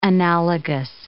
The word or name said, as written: analogous